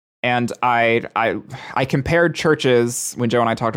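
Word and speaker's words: CHVRCHES: That's so confusing because I know it's pronounced Churches.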